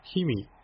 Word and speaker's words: 氷見: 氷見、氷を見ると書いて氷見と読むんですけど、